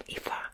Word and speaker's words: Aoife: And it's spelled A-O-I-F-E. A-O-I-F-E. You never think, you see it and you go, that doesn't look like Aoife. That looks like Aoife. Aoife. But it's Aoife and it's lovely. Absolutely love that.